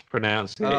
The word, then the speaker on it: pronunciation: Although eventually I found that I was trying to listen out for things to be annoyed that he said, and he did mispronounce pronunciation, and I was like, it's pronunciation.